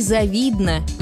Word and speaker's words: завидно: Согласно орфоэпической норме, ударение падает на 2-й слог. Ей завидно, ему завидно, нам завидно, отчего многие произносят неправильно.